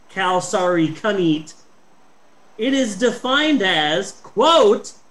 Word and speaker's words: Kalsarikannit: It's pronounced Kalsarikannit, and it is defined in Finnish.